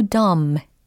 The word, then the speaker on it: dumb: 이건 감탄사로 쓰인 거고, I felt so dumb. 어, 여기서도 dumb 라는 철자인데, b는 여기서 묵음 처리가 됩니다. 그래서 dumb.